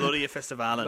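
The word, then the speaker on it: Melodifestivalen: Melodia Festivalen.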